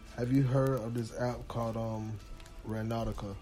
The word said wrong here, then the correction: Randonautica: Or um Randonautica. That's how you pronounce it. It's kind of like random and nautica put together, mm-hmm.